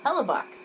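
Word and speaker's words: Hellebuyck: Oh, during intermission, we did figure out how to pronounce the Winnipeg goaltender's name. It's a Hellebuck.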